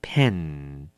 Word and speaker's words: pan: 第四个字 pan pan，名词平底锅。那么这个字呢跟笔有一点点像，它的发音，这个字你要记得了， pan 是嘴巴要拉扁，好，更宽一点要 pan。